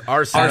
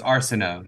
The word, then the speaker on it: Arceneaux: It's Arsenio. Arsenio. yeah. – Arsenio Hall. It is. It is. That's closer. – Actually, it's Arsenio. You were you were close.